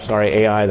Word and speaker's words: Ai: Nothing could stand in their way, or so it seemed. Suddenly, the next city on the bus route at AI, the Cambridge pronunciation, I is the perhaps more authentic one.